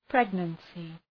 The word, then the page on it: pregnancy: ‘pregnənsı